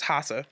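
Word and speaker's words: Lhasa: Well, when people would come in and they would try to take over the capital, which is Hasa, Tibet, which has an L in the beginning, but the L is silent in my my brain, so it's Hasa.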